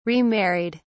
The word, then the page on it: remarried: British English